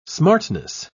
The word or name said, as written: smartness